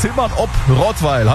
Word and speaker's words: Zimmern ob Rottweil: Damit Micha aus Zimmern ob Rottweil.